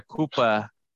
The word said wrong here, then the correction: Koepka: Brooks going over as well. So he's another. – It's Koepka. – Oh, Koepka, sorry.